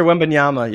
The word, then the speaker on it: Wembanyama: How do you say his last name? – Victor Wembanyama, yeah.